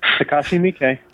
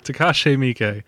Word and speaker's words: Takashi Miike: Takashi Miike. – Takashi Miike.